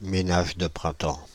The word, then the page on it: ménage de printemps: /me.naʒ də pʁɛ̃.tɑ̃/